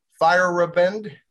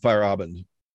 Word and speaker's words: Feyerabend: And I'll probably mess up his last name, Paul Feyerabend. – I always pronounce it Feyerabend, but I don't know. That's what, sort of the German pronunciation, Feyerabend.